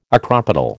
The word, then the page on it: acropetal: American English